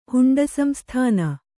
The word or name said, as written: ಹುಂಡಸಂಸ್ಥಾನ